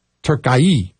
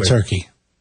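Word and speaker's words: Türkiye: This is how it's spelled. T, that U with the two little dots above it. R-K-I-Y-E. – Okay. – Turkey.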